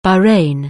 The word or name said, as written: Bahrain